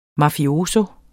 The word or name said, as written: mafioso